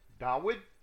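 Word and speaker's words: Dawid (דוד): Boaz is a prince of Israel and is in the direct line of King David, Dawid, properly pronounced, and of our Messiah.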